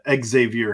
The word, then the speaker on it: Xavier: Xavier Henderson. Xavier Henderson. We'll make sure to pronounce both the X and the Z as he spells it, not as the Muskies do.